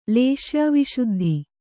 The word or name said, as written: ಲೇಶ್ಯಾವಿಶುದ್ಧಿ